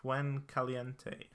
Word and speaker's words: Fuencaliente: But they caught up to him and took him away from the mountain to a nearby town, Fuencaliente.